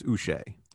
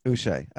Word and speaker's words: Uche: It's like Boucher without the B. That's how you pronounce it. It's Uche. – Uche.